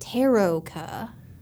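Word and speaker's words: Tarokka: It's definitely Taroka. – Yeah, was Taroka. How we pronounced it in the office when we were doing it all.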